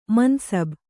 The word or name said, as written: ಮನ್ಸಬ್